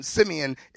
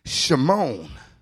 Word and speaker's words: Simeon: And Simeon is pronounced Shimon.